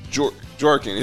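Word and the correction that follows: Bjorkgren: His name is Bjorkin. – Yeah, Bjorkin.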